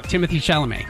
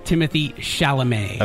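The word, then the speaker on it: Timothée Chalamet: How do you pronounce this guy's name? – Oh, Timothy Chalamet.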